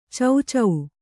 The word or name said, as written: ಚೌಚೌ